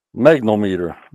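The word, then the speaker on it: magnetometer: Magnometer. Magnometer. That's how I would say it. – Yeah, you got it. Yes, you got it.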